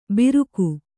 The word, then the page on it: ಬಿರುಕು: biruku